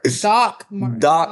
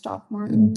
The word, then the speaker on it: Doc Martens: Isn't it DR though? – It's DOC. – Oh, really? – Everybody spells it different, but it's pronounced Doc Martens.